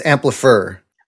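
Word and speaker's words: Amplifr: So it's Amplifer. – Right. – Because it's A-M-P-L-I-F-R.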